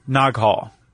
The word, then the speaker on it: Naghol: And, Pentecost Island is where the origin of land diving or something called Nag Hall. – Yeah. – Or Nag Hall. – N-A-G-H-O-L? – Yeah. – Yeah. – Not the easiest word to pronounce. – You did fine. – Nag Hall.